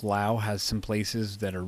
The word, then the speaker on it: Laos: I think there are places in like Laos, you know, I used to call it Laos, but that's not cool. I don't know what I'm, you know, you have to pronounce it the right way. It's Laos.